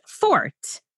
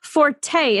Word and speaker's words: forte: The word that means something that someone excels at is supposed to be pronounced fort, meaning that it is one strength. Forte is actually an Italian term that is used in music.